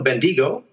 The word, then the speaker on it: Bendigo: I then go up to a little place called Bendigo. Is that the right pronunciation? Or Bendigo? Bendigo. which is another presentation.